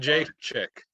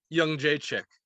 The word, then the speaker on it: Jędrzejczyk: Jędrzejczyk. – Joanna what? – Jędrzejczyk. It's not pronounced anything like it said.